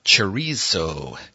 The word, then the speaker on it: chorizo: The spelling, C-H-O-R-I-Z-O. This is a Spanish word, and it's pronounced chorizo with a T-H, chorizo. And those of you golf fans that may have heard of a Spanish golfer back in the day, he was a Masters champion, Jose Maria Olazabal. He had a Z in his name. So that's how this word tends to be pronounced.